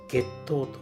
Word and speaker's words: 月桃: 月桃というのはですね、こちら月の桃と書きます。月の桃と書いて月糖というものでして、ご存知の方いらっしゃいますかね。